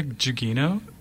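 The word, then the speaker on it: Gugino: Giuliano? I don't know. – I'm going to say Giuliano, probably? – Maybe, yeah. – Silent G? – Is it Carla Giugino? – Yeah.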